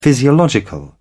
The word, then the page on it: physiological: British English